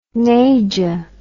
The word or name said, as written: naja